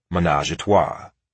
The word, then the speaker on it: Menajahtwa: In 1994 alone, Ruthless released albums from 9 different artists, including little-remembered acts like Nashville rapper Pistol and female duo Menajahtwa, pronounced Menage a Trois.